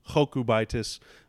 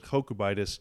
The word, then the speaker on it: Jokubaitis: I watched videos hearing him say his name, and he pronounced it like Jokubaitis.